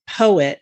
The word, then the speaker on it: Poett: And the woman who's in it, her name is Elizabeth Poet, and it's spelled P-O-E-T-T.